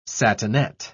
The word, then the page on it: satinette: British English